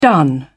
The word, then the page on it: done: British English